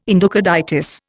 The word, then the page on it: endocarditis: Indian